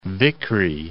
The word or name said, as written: Vickrey